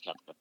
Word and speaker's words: Koepka: And it was just like, you know, and we're still trying to figure out how to pronounce his name at that point. Is it Koepka? It's Koepka. But, yeah, you know, it was, I don't know why that stands out.